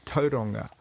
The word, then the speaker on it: Tauranga: It's Tauranga, if I'm pronouncing that right.